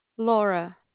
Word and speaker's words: Laura: You say your name is Bora? – Laura, with an L. – Oh, Laura. Hi, L-A-U-R-A.